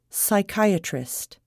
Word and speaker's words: psychiatrist: The letter P is not pronounced at the beginning of many words. Psychiatrist, pneumonia, psychotherapy.